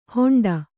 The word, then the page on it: ಹೊಂಡ: Kannada